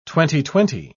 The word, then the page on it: twenty-twenty: British English